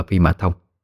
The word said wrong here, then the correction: Phi Nhã Thông: nhưng xin đính chính lại, đó là Phi Nhã Thông thì mới đúng.